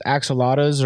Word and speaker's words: axolotl: Real quick, like you were talking about those axolotls or what, is that how you say it?